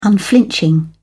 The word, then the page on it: unflinching: British English